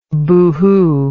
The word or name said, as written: boohoo